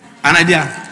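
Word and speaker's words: anaideia: A-N-A-I-D-E-I-A. A-N-A. A-N-A. What is it? Someone is trying to help me pronounce it. An idea. And that means shameless.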